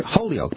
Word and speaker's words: Holyoke: Like, we have a town here in western Massachusetts that's spelled Holyoke, H-O-L-Y-O-K-E. So you're Holyoke, but it's pronounced Holyoke, like Holyoke.